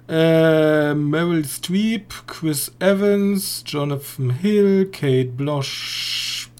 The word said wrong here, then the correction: Blanchett: Spricht man die Blochet? Oder einfach Blow? – Kate Blanchett.